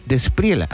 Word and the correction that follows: De la Espriela: ¿Así se pronuncia? ¿Despriela? – De la Espriela.